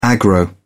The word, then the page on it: aggro: British English